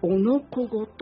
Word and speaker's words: 男の子: おのこごと、おのこごは男の子と書いて、男子と書いて、おのこごと、空に踊るや鯉のぼり。